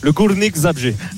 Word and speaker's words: Górnik Zabrze: Belle prononciation d'ailleurs Arnaud pour le Górnik Zabrze.